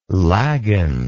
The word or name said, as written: laggin